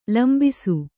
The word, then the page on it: ಲಂಬಿಸು: lambisu